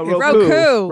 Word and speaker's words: Roku: Roco will have a canary. – No, Roku. – Roku. Say Roku.